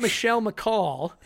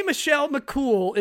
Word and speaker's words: Michelle McCool: It's not Michelle McCall. It's Michelle McCool. – How do you say it? Michelle McCool. – Michelle McCool. – Michelle McCall. – Yeah, no, I say Michelle McCool. That's how you say cool.